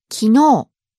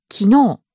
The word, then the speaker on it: 昨日: So we have words like yesterday, 昨日, but it's spelled as 昨日. – Right, but you just said it with a really long O sound at the end. – 昨日.